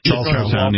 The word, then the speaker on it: Charlestown: Is it Charlestown or Charleston? – Charlestown. Yeah.